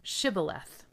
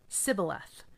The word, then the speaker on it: Shibboleth: If the man said, "No, I'm not," they would tell him to say, "Shibboleth." If he was from Ephraim, he would say, "Sibboleth," because people from Ephraim cannot pronounce the word correctly.